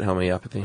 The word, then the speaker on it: homeopathy: Dude, So we're going to hear from so many people just for having even uttered the word homeopathy. – Is that how you say it, homeopathy? – No, it goes both ways.